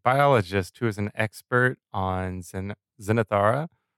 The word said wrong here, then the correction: Xenarthra: Is that how you say it? – Xenarthra, I think. Xenarthra. Xenarthra uh, big time simp for his wife Sophia.